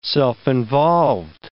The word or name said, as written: self-involved